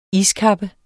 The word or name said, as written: iskappe